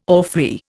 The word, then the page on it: orphrey: Indian